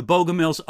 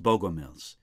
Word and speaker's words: Bogomils: Sometimes in the literature, I find their name written with an E in it and sometimes without, but I think it's pronounced Bogomils.